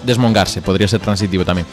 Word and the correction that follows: desmondongarse: Desmondongarse. Perdón, lo dije mal, ¿no? – Sí. – Bien. Desmondongarse. Que viene de mondongo.